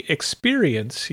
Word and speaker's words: experience: When you say experience, you have to make sure that you can hear all four syllables of the word.